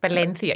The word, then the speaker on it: Valencia: Apparently that's how you say it. Valencia. Yeah, Valencia.